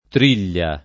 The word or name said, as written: triglia